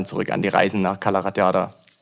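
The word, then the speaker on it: Cala Ratjada: Es müsste Calaratjada sein. – So einfach spricht man das, Calaratjada? – Ja, ja, okay.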